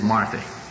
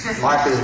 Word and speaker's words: Marthe: So Martha and I got on the airplane, and by the way, my wife's name is Martha, M-A-R-T-H-E. You know, I say that and people look at me and say, that poor nut doesn't even know how to pronounce his wife's name. But I do.